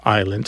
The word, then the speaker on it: island: So we don't pronounce the S in island because the French don't pronounce the S in Isle.